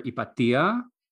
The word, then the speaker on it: Hypatia: In Greek, I would call her Ipatia.